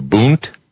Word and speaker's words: Bund: Even my research in Circuit Chautauqua, he goes into a lot of detail about the what people named the Bund, the Bund in German is pronounced B-U-N-D. – Right. – The D is pronounced like a T.